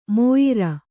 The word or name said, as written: ಮೋಯಿರ